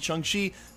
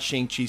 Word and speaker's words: Shang-Chi: For the record, if you're wondering why I pronounced it Shang-Chi, that official pronunciation is coming from Simu Liu himself, who educated the entire world. It is not Shang-Chi, so I will endeavor to say it correctly while we cover it here at the channel.